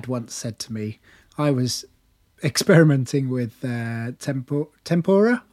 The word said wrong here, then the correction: tempera: Am I saying that right? – Tempera. – Tempera. Tempura is the deep fried vegetables, isn't it? – Yep.